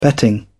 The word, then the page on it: betting: British English